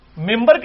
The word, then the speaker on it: منبر: ممبر کی سپیلنگ کیا ہے؟ من بر, لیکن پڑھا کیا جاتا ہے؟ کیوں؟ کیونکہ نون کے بعد, نون ساکن کے بعد بار ہے, یہ نہیں ہوتا لکھا جاتا ممبر.